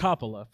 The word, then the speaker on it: Coppola: 1989's The Outsiders, directed by Francis Ford Coppola. – And you said that, right? – You say that. – You said it right. – It's Coppola, for sure.